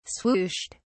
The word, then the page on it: swooshed: British English